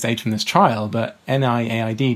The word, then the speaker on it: NIAID: NIAID.